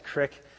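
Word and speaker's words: Creek: I was a student pastor, and I wound up spending 5 years in this church, the West Crick United Methodist Church, and it's C-R-E-E-K, Crick. That's how they say it there.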